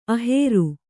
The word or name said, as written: ಅಹೇರು